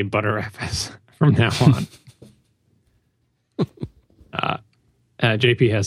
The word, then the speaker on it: Btrfs: Maybe that's all I could get out of his Twitter handle or email or something, was the first to point out that BTRFS is pronounced ButterFS. I had heard that pronunciation, but I wasn't aware it was the official. Apparently, it is the official pronunciation.